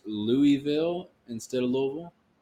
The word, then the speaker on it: Louisville: From Louisville, not Louisville. I heard somebody say Louisville the other day in an interview, and I almost threw the fuck up. I'm like, nope, that's not how you say it.